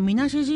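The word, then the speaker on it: 水無: 飛騨一宮のみなし神社って水なしって書いてみなしと読むんですけども、みなし神社の奥宮があるとされている暗い山ですね。登山やなんかされる方はね、お山行かれる方多いかもしれないんですけど、みなし神社っていう神社自体は、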